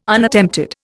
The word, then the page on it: Unattempted: Indian